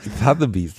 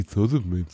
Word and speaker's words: Sotheby's: Sotheby's. Jetzt ist es schon besser. Es wird langsam besser. Beim ersten Mal hast du sowas gesagt wie Sotheby's.